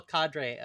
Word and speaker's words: cadre: I don't know how to pronounce this word. I mispronounce it every time. I think it's cadre. C-A-D-R-E. Yeah. – I think it's cadre. – I have a small cadre.